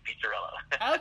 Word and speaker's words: Pizzarello: It's pizza, just like the food, Pizzarello.